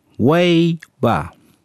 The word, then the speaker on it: Waiba: The first word I want to teach you is Waiba. It's pronounced Waiba.